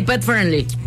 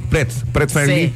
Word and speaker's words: Pet Friendly: La parte de Pretty Friendly, ¿sí se dice así? – Sí, Pet Friendly. – Pet Friendly. Sí.